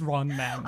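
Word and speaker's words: Ron Manne: You can spell it M-A-N-N-E if you want, but it's Ron Man.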